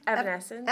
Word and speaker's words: Evanescence: Evanescence.